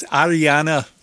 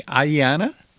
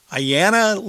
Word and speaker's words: Aiyana: It's Ariana Lee Anderson. I'm not really sure how to say it. A-I-Y-A-N-A. Ayana? There's no R in there. I want to put an R in there. Ayana Lee Anderson and a track here called Housebroken.